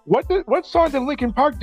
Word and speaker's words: Linkin Park: They have Paramore and Linkin Park, okay? – Hey. Stop real quick. – Yes? – Linkin Park has an R in it. Pronounce the damn R, you fucking northerner.